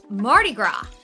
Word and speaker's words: Mardi Gras: And today is March 1st, Mardi Gras, right? – Yeah, that S is silent, huh? – Yeah. Yes. Okay. Like Illinois and Des Moines.